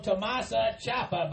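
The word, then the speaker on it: Tommaso Ciampa: It's called Tomasa Ciampa, by God. Now try it. – Tomasa Ciampa. – Just think of a big old chalupa.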